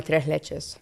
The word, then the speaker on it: tres leches: Give me a little lesson on the pronunciation. – Tres leches. – Say it again. – Tres leches. – Tres leches. – Leches. Leche. L-E-C-H-E-S.